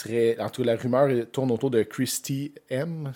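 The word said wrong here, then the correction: Hemme: Christy Hemi. Hemi, que ça se prononce.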